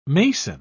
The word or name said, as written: mason